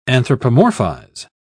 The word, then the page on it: anthropomorphize: British English